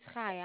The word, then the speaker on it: Chaya: It's Chaya.